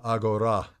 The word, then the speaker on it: agora: but let's talk about this thing called agora. Is that right? Is that how you pronounce it? – Agora. – Agora.